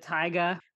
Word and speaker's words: Tyga: There's a song with Tyga, which is pure ridiculous, where it's like, shake it like a snow Tyga? – Globe. I think it's, did I say it wrong? – No, that's like, right.